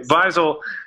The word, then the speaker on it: Wiesel: Weisel, do you pronounce the with the German W there? – Yeah. – Okay. Weisel, which is spelled W-I-E-S-E-L.